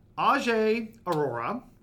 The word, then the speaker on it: Ajay Arora: We have Ajay Arora.